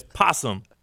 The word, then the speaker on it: opossum: I just looked up the pronunciation. It says possum.